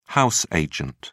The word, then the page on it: house agent: British English